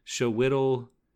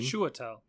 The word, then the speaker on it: Chiwetel: It also has my boy, whose name I'm going to mispronounce, Showittle Ejiofor? Ejiofor? Copley is his name. – Chiwetel.